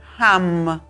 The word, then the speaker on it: ham: El mantra o sílaba sagrada para abrirlo se llama ham, como jamón, H-A-M, ham.